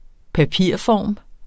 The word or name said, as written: papirform